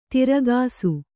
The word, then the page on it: ತಿರ್ಗಾಸು: tirgāsu